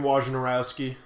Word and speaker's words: Wojnarowski: According to Adrian Wojnarowski, I think that's how you pronounce his last name. – Yeah. – Wojnarowski.